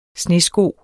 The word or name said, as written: snesko